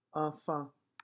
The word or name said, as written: enfant